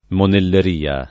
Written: monelleria

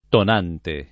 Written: tonante

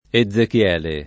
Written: Ezechiele

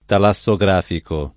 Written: talassografico

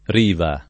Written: riva

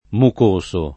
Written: mucoso

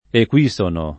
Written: equisono

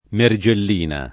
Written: Mergellina

